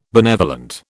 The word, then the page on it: benevolent: British English